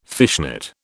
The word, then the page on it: fishnet: British English